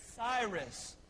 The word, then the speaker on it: Cyrus: Cyrus. Starts with a C and rhymes with Iris.